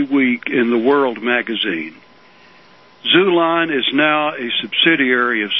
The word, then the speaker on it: Xulon: Well, Zulon is a so-called Christian publisher and printer. – How do you spell that? – It's the same X in Xerox or xylophone. Okay. X-U-L-O-N. – Very good. X-U-L-O-N.